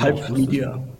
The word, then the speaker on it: Pulp Media: Und Frage 1, wie spricht man eure Firma richtig aus? Ich gestehe. – Pulp Media. – Pulp Media, also Englisch.